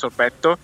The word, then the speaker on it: sorbetto: I'm not saying this because you discussed sorbet, which in Italian we pronounce sorbetto, and here they call granita, which is basically ice melted with fruit.